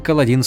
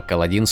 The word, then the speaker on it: Каладин: Он произносил «Каладин» с ударением на последний слог вместо обычного «Каладин» с ударением на первую «А».